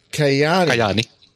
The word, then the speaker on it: Kajaani: What, Kajaani would be Kajaani. Mm-hmm. It's a silent J.